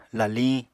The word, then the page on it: laliñ: Apatani